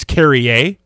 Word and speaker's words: Carrier: Or actually, apparently, by the way, it's Carrier, which I did not know. He uses a French pronunciation.